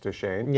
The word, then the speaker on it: Deschain: I can spell it D-E-S-C-H-A-I-N, but I think it's pronounced Tashane.